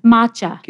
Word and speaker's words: Matcha: Matcha. – Can you spell it? – M-A-T-C-H-A.